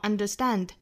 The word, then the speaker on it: understand: understand 重读呢是在后面的元音上，在最后一个音节上哈，大家不要读成了 understand，是 understand da da da。